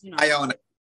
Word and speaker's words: Iona: Iona. – Iona.